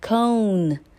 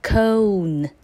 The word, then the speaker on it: cone: Number 7, cone, cone. 那你听到是 o, 所以它是 oe, 会发 o.